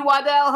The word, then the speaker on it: Waddell: Now we want to welcome to the Jimmy Star Show with Ron Russell, stuntman, actor, the original Ghostface, Lee Waddell.